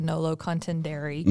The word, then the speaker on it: nolo contendere: It's actually pretty good. I mean, if you listen to lawyers say it, they just say nolo contendere, you know, or dare or dare.